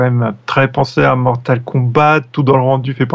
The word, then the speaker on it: Mortal Kombat: Par contre, tu prononces toujours le T à la fin de Mortal Kombat. Oui, parce que c'est un jeu euh, anglais.